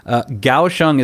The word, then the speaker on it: Kaohsiung: Kaohsiung is spelled K-A-O-H-S-I-U-N-G. So even though it's spelled with a K, it's pronounced with a G sound.